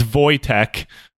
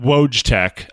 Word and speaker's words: Wojtek: It's Wojtek, not Wojtek.